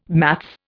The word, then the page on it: maths: Indian